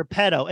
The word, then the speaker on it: Petto: I do think it's crazy that they call his character pedo. It's spelled P-E-T-T-O, but the way you hear it is P-E-D-O.